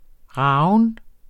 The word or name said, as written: ragen